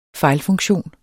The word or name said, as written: fejlfunktion